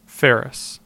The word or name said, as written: Ferris